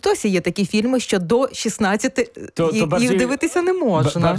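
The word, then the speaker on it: ДО16: І, власне, як вимовляти цю назву? Я вже знаю. До 16. А пишемо Д, О і цифрами 16. – Д, 0, 16, але читається до 16. – Так, так, до 16.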